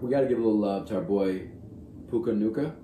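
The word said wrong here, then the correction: Nacua: Puka Nakua. – Sorry, I always butcher that. Puka Nakua.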